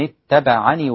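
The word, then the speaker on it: اتبعنِ: وإذا وصلت طبعا أنت مش هتثبت الياء لكن هتثبت كسرة النون التي سكنتها من أجل الوقف، فيكون النطق ومن اتبعني وقل، ومن اتبعني وقل من الاتباع.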